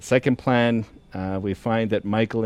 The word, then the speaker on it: Michal: And so I think it's important to understand that it's M-I-C-H-A-L. So some will say Michal, to try to differentiate. But what I've been told by the scholars, the actual pronunciation is Michael, but it's a female spelling and version of the name.